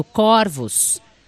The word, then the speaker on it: corvos: Pesquisei e descobri que, diferentemente do singular, no plural, a palavra corvos é pronunciada com o O aberto. Corvos.